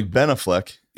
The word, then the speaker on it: Ben Affleck: My heart will melt if I get a text from Ben Affleck and says, hey, man, Ben Affleck.